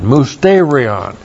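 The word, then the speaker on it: mysterion (μυστήριον): Looks like that in the Greek, M-U-S-T, long E-R-I-O-N, mysterion. Unfortunately, it's been transliterated mystery.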